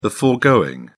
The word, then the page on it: foregoing: British English